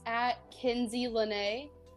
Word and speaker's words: KenzieLanae: Yeah, my Instagram is @KenzieLene. It's spelled KENZIELANAE. And then my Twitter is Ginger Kenzie.